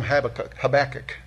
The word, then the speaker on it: Habakkuk: Well, you could even call him Habakuk. I don't know, you can call him whatever you want, but Habby, if you like that, if you like short nicknames or whatever, but I call him Habakuk is how I pronounce it.